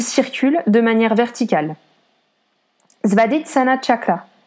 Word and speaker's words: chakra: Je tiens également à préciser qu'en sanskrit, chakra se prononce chakra, le premier chakra donc.